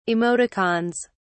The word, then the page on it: emoticons: British English